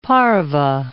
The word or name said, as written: pareve